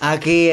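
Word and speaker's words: Aquí: Aki is here. It's spelled A-Q-U-I.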